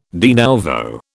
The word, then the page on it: de nouveau: British English